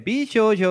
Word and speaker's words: Bishoujo: A, aquela, aquele traço em cima do O é a tônica, é Bishoujo é Game.